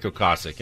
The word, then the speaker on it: Ocasek: I had never heard of Rick Ocasek until.